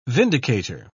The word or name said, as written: vindicator